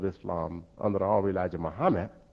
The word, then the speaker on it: Muhammad: But we pronounced it Muhammad. That's exactly the way we've told pronounce it, and that's how we pronounce it.